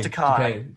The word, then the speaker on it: Takei: George Takei.